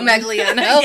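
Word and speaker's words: Manganiello: I can never say his name. Joe Magnano. – Joe Magliano. – Yeah.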